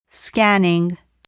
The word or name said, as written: scanning